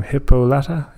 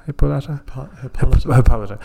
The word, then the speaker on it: Hippolyta: Hippolyta. Hippolyta.